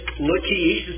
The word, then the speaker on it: Lachish: Then Hezekiah, king of Judah, sent to the king of Assyria at Lachish, that is, Lachish is the better way to pronounce it, saying, I've done wrong.